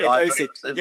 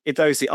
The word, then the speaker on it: Idosy: Idosi. Yeah, so, I mean, we're not fussy about how people pronounce it. You know, Idosi, I-D-O-S-Y.